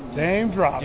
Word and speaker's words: Daym Drops: Daym Drops, Daym indeed.